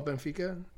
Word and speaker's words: Benfica: Oh, Benfica.